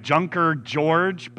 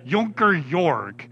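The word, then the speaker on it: Junker Jörg: I don't know how you go from Martin Luther to Junker Jorg, but that's, yeah, it's spelled, it's with J, so it looks like Junker George, but yeah, it's Junker Jorg.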